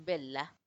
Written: belha

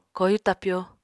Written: koyu tapyo

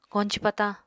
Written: koñci patañ

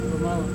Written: romawa

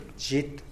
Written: zhit

